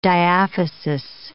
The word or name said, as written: diaphysis